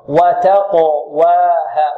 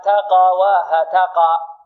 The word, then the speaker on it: وتقواها: وتقواها وتقواها، بعض الطلاب ماذا يقول؟ وتقواها تقواها تقا، يرقق القاف وينطق بها ماذا؟ مفتوحة إن لم تكن مائلة إلى الفتح في القلقلة. وتقواها، انظر معي خطأ الآن، عندنا خطأان فيها، يقول تقواها تقا تقا، يميل الفتحة القلقلة للفتح ويرقق ماذا؟